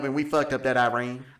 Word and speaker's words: Iran: I like Iran. – But... Iran. That's technically how it's pronounced, but, you know, what do you do?